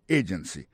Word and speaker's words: agency: agency pour mot agency hein, accent tombé sous première syllabe là agency deux autres syllabes qui derrière au faible an